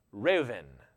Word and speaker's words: Reuven: And this would have been pronounced Reuven. The B would be a V sound.